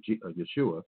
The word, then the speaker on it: Yeshua: And so Jesus, or Yeshua, that's his Hebrew, that's how you say his name in Hebrew, Yeshua.